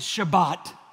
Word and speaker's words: Shabbat: We've learned that word in the Hebrew, you pronounce it Shabbat.